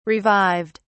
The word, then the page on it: revived: British English